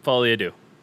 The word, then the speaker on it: Folie à Deux: Folie à Deux. – Yep, Folie à Deux.